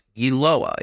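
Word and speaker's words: Yiloga: Aha, actually the 7 article has a pronunciation, so the G is silent. Yiloa, it's Tiwi for football.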